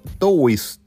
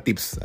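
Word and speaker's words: Toews: porque la weá se escribe T-O-E-W-S. O sea, habría que leerlo como Towis o Toeus, Toeus, una o Tovs. Pero la weá se pronuncia Tips, así que eso, me, me llamó mucho la atención cómo se pronunciaba ese apellido en aquellos tiempos.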